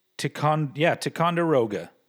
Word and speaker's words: Ticonderoga: This, Ticonderoga, am I saying that right? Ticonderoga? – Let me take a look at it. So that, I would say that is Ticonderoga.